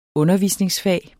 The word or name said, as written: undervisningsfag